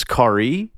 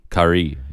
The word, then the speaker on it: Carré: I believe the pronunciation is Carré. – Carré, yeah. – C-A-R-R-E with an accent over the E if you're trying to find this online at some point.